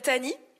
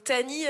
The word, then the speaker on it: Tahnee: Moi, je me présente, je m'appelle Tani. Donc Tani, euh, ça s'écrit T-A-H-N-E-E, e.